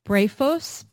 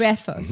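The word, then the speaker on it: Brephos (βρέφος): And in Luke, you know, John the Baptist leaps in Elizabeth's womb, and Luke refers to him as Beatros, or can you pronounce it for me? Brephos, yes. – Brephos.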